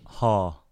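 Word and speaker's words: Heo: Haw. – Haw? – Yeah. – Just Haw. – Yeah, it's like H-E-O. E-O is kind of like a Spanish diphthong.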